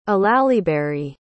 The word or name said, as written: olallieberry